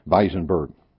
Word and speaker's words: Weinsberg: I've entitled it "The Wives of Weisenberg," Weinsberg in German. W is pronounced as a V, Weisenberg.